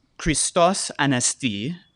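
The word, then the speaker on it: Christos Anesti (Χριστός ανέστη): Now, in Greek, this is using modern pronunciation, they say, Christos Anesti, and in response, they say, Aledos Anesti.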